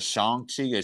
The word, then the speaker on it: Shang-Chi: I saw Venom and I saw, I think it's, is pronounced as Shang-Chi. Yeah, it might be, but like Shang-Chi was, that's right.